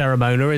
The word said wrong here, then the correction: Cremona: is it? Well, how do you pronounce it? – It is a Cremona J.